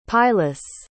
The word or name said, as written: pilus